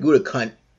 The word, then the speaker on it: Gutekunst: Matt LaFleur and Brian Gutekunst. That's how you pronounce his name, but that's his last four letters.